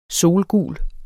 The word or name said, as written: solgul